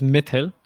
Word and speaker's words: Mithil: Yeah, so it's pronounced as Mithil. Yeah. So, but I've gotten used to a ton of pronunciations for my name. I mean, now I shorten it to like myth, like as in like mythology.